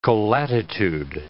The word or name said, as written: colatitude